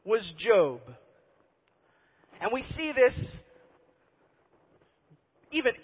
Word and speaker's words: Job: J-O-B. And don't ask me why it sounds like job. That's just how it's pronounced in that day and age. It's Job. All right?